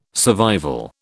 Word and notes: survival: British English

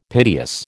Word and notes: piteous: American English

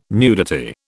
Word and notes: nudity: British English

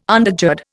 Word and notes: undergird: Indian

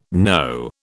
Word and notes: know: British English

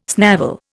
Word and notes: snavel: Indian

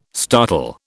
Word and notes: startle: British English

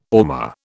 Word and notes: ormer: British English

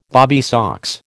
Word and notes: bobby socks: American English

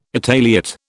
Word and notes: Italiot: British English

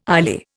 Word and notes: ILEA: Indian

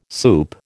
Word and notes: soup: American English

